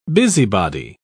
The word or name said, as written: busybody